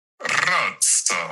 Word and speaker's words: Rosso: I know you can't roll your R's. – I can't. You want to say it for us? – Rosso.